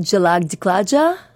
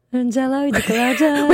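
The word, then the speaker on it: Jhalak Dikhhla Jaa: Now, pronounce it for me again. – Indian. It's called Jalak Diklaja. Jalak Diklaja. – Wait, I'm going to try and pronounce it. Wait. – Jalak Diklaja. – Jalak Diklaja. It's kind of wrong.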